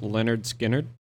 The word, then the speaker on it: Lynyrd Skynyrd: It's pronounced Lynyrd Skynyrd.